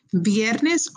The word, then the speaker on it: viernes: Remember, we talked about you can say with a V as in Victor or as a B as in boy, makes no difference. Viernes or viernes, we're good.